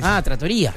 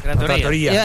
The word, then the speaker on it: trattoria: Trattoria. – Trattoria. – Ahí sí con el acento en la I. – En los lugares donde se comen pastas. – Sin correcto. – Tilde, pero con acento.